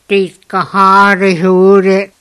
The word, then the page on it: tîtkahârihûrit: Skiri